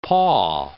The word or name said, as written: paw